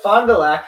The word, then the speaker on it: Fond du Lac: It's Fond du Lac, Michigan. Is that correct? Did I pronounce that right? – Yeah, Fond du Lac, yes.